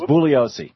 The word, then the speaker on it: Bugliosi: I want to thank you very much for having Vincent Bugliosi on. – Yeah, except he doesn't pronounce the G. – And if you say it that way, he gets real offended. So it's Bugliosi.